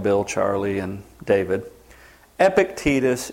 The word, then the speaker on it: Epictetus: Epictetus is how you say that word.